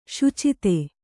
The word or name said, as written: ಸುಚಿತ್ತ